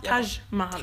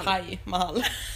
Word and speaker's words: Taj Mahal: Taj Mahal. – Uh-huh. – Det stavas T-A-J. – Taj Mahal. – Taj Mahal. – Silent J. Taj Mahal.